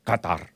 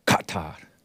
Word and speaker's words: Qatar: Qatar. You're supposed to choke on it. Qatar.